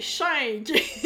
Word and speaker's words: Shink: Audrey Schenk, c'est comme ça qu'on prononce Non, mais je t'aime pareil, c'est Schenk.